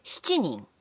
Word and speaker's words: 七人: 四人 Also, with seven people, adding 人 to 七 makes 七人 But the other pronunciation for seven, 七人 is also fine.